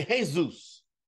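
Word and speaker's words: Jesús: Now, how do Spanish people pronounce the name Jesus? They say Jesus. Jesus.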